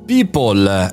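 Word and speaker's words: PIPL: People è la parola eh, detta, pronunciata all'europea, eh, si scrive P-I-P-L e mira a proteggere, secondo la traduzione, i diritti e gli interessi degli individui.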